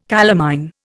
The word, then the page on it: calamine: Indian